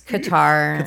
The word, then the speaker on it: Qatar: I mean, I hate to say knocked up, but remember when I said I didn't go to Qatar. Qatar, however that's supposed to be pronounced. – It's Qatar. – Qatar, okay.